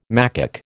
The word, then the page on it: macaque: American English